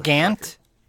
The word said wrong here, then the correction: Gwent: You play Gant? – It's pronounced Gwent. – Oh, you both can like I said, Gant. – Gwent.